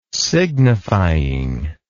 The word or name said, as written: signifying